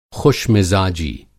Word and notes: ख़ुशमिज़ाजी: خوش مزاجی

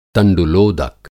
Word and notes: तंडुलोदक: Hindi

